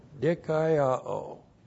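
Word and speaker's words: Dikaiao (δικαιόω): D-I-K-A-I-O, long O. Dikaiao.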